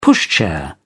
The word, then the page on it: pushchair: British English